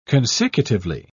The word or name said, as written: consecutively